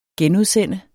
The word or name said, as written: genudsende